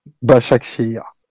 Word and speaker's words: Başakşehir: Comment Başakşehir. – Bachak Shair, Donc, euh, excusez-nous, euh, amis turcs.